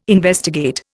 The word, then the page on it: investigate: Indian